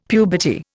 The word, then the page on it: puberty: Indian